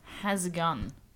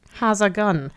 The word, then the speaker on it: how's it going: So instead of pronouncing it, how's it going, mm-hmm. you pronounce it more like has a gun. – Has a gun. – Has a gun. But like has a gun. A slower, like how's a gun? – Right, try.